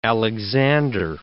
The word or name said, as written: Alexander